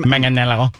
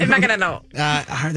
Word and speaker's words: Manganiello: Sofia Vergara and Joe, how do I say his last name? – Manganiello. – Nailed it. – That's how she kind of says it. – Manganiello.